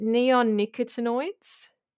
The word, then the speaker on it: neonicotinoids: In particular, I'm going to probably pronounce this wrong, but is it neonicotinoids? – Yeah, that's pretty good.